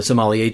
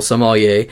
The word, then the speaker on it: sommelier: So he writes in and says, I'm not a native French speaker, but I think you've been adding an extra hard E syllable to sommelier, making it sound like Somalia. The African nation. For what it's worth, he has a friend who is a professional sommelier, and he pronounces the word sommelier. I think it's actually an issue of we were putting the accent on the wrong syllable.